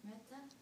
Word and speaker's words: metta: So when you were talking about, how do you pronounce it, metta? – Metta, yeah.